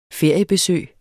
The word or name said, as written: feriebesøg